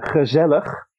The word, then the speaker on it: gezellig: gezellig. – Gezellig. – Gezellig. – Gezellig. – Yes, you have to pronounce it like you just had a herring, and then you try to say it.